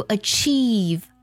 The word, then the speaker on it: achieve: 比如 achieve 拼作 A-C-H-I-E-V-E，这里呢 C-H 发的就是吃的音啦。 Achieve 指的是实现的意思。还有 Bush B-U-S-H 指的是灌木丛的意思。